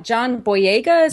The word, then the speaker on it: Boyega: And I felt like I really loved the fact that, is it John Boyega? Is that how you say his name? – Yeah.